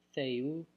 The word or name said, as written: Theou